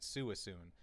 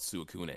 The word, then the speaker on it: Suicune: Like when you said Suicune, as a kid, I always pronounced it Suisune. So. – That's exactly how I did it. I mean, I listened to another Pokemon podcast and they call it Suicune.